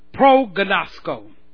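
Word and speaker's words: proginosko: Just put the P R O in the front of Gnosco. Prognosko.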